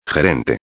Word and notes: gerente: espanhol

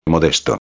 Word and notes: modesto: espanhol

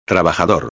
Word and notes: trabajador: espanhol

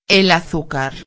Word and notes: el azucar: espanhol

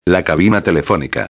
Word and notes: la cabina telefonica: espanhol

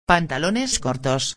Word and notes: pantalones cortos: espanhol

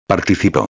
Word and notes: participo: espanhol

